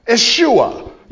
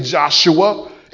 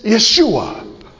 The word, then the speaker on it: Yeshua: Yeshua. You don't pronounce the J. You don't say Joshua. It's Yeshua.